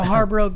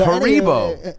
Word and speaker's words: Haribo: Well, there's a lot of good gummy bears because I didn't think they still taste like the Haribo gummy bears. – Okay, so Haribo. – That's not how it's said. – Haribo.